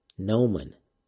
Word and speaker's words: gnomon: So if you want to learn more about the gnomon, do a Google or Bing search for Apollo gnomon, and it's spelled kind of funny. Gnomon is spelled G. N-O-M-O-N. That is G-N-O-M-O-N. So it's not, the G is silent, so that's a little tricky.